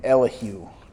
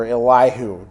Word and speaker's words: Elihu: has to do with the somewhat mysterious man named Elihu, or Elihu, depending on how you've heard it pronounced.